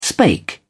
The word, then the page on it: spake: British English